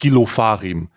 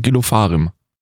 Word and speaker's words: Ofarim: Nicht Gil Ofarim. – Doch, das ist ein jüdisch ausgesprochen Gil Ofarim.